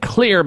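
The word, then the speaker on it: Xlear: And Clear, by the way, is spelled with an X, X-L-E-A-R. The X stands for xylitol.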